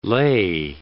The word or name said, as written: lei